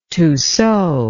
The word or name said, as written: tussaud